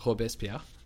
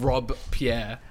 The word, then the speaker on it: Robespierre: I, well, one, don't say it like that. It's Robespierre. It's not two. It's not two words. It's one word. It's it's not Rob Pierre, all right?